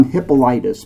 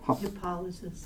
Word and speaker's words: Hippolytus: See, I want to call him Hippolytus, but it's not how you pronounce it. – Hippolytus.